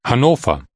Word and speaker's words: Hannover: Hanover is pronounced Hannover.